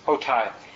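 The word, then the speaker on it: Hotei: Anybody know how it's pronounced? Hotai yeah, Bonnie just uh, gave some statues of that out.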